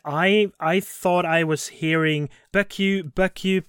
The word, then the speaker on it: Becciu: Hi guys, since you asked it, the correct pronunciation in Italian for the name of the cardinal is not Becciu, but it's Giovanni Angelo Becciu. Becciu.